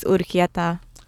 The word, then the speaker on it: Urquieta: My last name is Urquieta. – How the hell do you spell that? – Yeah, U-R-Q-U-I-E-T-A.